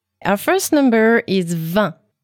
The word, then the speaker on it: vingt: Yes, and this is an important thing to know, because when you will count from 21 to 29, you will have to pronounce that T. – Right, but when you just say 20, you don't. You just say vingt.